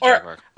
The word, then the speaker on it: Jaguar: He was in a Jaguar commercial with Mark Strong and Ben Kingsley. – There was a Jaguar. – Or how they say it, Jaguar.